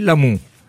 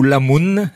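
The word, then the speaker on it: Lamon: Euh, alors, je pense qu'il faut le prononcer l'amont. Hein, euh, chez nous, ou Lamoun dans les Landes,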